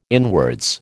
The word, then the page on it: inwards: American English